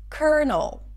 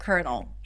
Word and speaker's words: colonel: as you're making the L sound. Null. Colonel. Lieutenant Colonel.